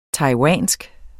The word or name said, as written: TAIWANSKE